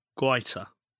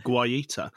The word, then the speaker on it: Guaita: Well, I'd have gone for Cahill or Guaita. I've now got that correct. In terms of pronunciation, I've been saying Guaita for several months, so thanks for the lesson there.